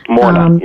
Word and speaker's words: Morna: Morna, yes.